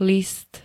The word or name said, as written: list